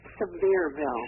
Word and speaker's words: Sevierville: Well, you know, the funny thing is, as I look at that word, and pronounce it again for me. – Sevierville. – Sevierville, which is, of course, not so severe.